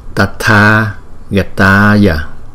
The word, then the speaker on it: Tathagataya: Đó, Tathagataya. Đó, chữ T, chữ H chúng ta đọc là ta, ta, ta, ta. Nó không phải là thờ mà là ta, tờ hờ đó, ta, ta, Tathagataya, thấy không?